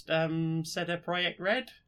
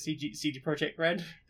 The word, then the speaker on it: CD Projekt Red: I don't know if it's, like, not a common one, but I will generally pronounce Seda Project Red. – Yeah. – In what I believe is closer to its correct pronunciation. – Yeah. – But I know, obviously, most people pronounce it CD Projekt Red.